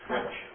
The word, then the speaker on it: Rich: In the July 1974 issue of Truth Magazine, the periodical of the Grace Gospel Fellowship, Mr. Ray Reich, or Wright, Rich, how do you say it? – Rich. – Rich.